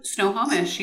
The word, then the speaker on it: Snohomish: Snohomish.